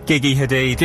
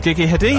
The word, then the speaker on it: Gigi Hadid: Gigi Hadid. – Gigi Hadid.